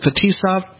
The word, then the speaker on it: Fetisov: To a certain extent, the documentary focuses on Slava, is it Fetisov? Did I pronounce it correctly? – Yeah, that's correct.